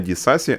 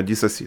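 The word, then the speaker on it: Disasi: Вибачте, ді... не Дісасі, а Дісасі, так? – Ну, тому що француз е, наголос на останню голосну.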